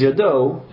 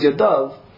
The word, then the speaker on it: Yadav: It's written, that word, although it says, it looks like it says Yado, it's pronounced Yadav, it's written without a Vav, without a Yud, because of the fact that it's an indication that even though it's pronounced plural, it really means a singular.